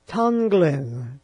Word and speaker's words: Tonglen: And one was, I can pronounce the word, I always have trouble with the word, but Tonglen, T-O-N-G-L-E-N, would kind of be the English word for it.